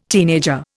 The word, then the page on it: teenager: Indian